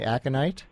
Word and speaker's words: aconite: And you'll tell me which of these I'm mispronouncing, because I actually have not heard of all of these, like aconite. You say aconite? – Yes.